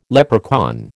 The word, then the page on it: leprechaun: American English